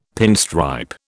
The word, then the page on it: pinstripe: British English